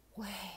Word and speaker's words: Yahweh: So it's the same name that we would say in the Hebrew fashion. Yahweh. That's how you pronounce the name of God.